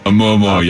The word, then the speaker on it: Oumuamua: Oumuamua, yeah.